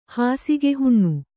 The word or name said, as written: ಹಾಸಿಗೆಹುಣ್ಣು